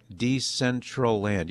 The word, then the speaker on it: Decentraland: Decentraland, am I saying that right? Decentraland, yeah, Decentraland.